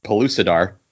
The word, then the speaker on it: Pellucidar: Its function was to show that all fictional universes from Edgar Rice Burroughs, Pellucidar.